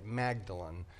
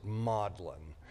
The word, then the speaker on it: Magdalen: It looks like you'd say it Magdalen. But they pronounce it Magdalen.